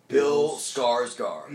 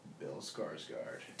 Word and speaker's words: Bill Skarsgård: Well, I don't even know how to pronounce this guy's name. The guy who played the new it. – Bill Skarsgård. – Yes. Bill Skarsgård.